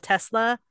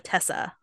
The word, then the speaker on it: Tesla: Like somebody had a Tesla and he called it a Tessa or a Telessa.